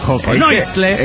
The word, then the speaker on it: joconoistle: A nosotros nos da Joconoistle. – ¿En qué O? – Joconoistle. El acento en la segunda O.